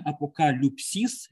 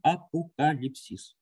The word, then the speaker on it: апокалипсис: Видите, есть два произношения, апокалюпсис, это научное, такое университетское академическое произношение, а в церкви привычно другое византийское произношение, когда звук «ю», «ю» буковка, читается как «и», поэтому появляется слово, знакомое вам, «апокалипсис».